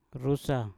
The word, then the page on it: rusa: mpur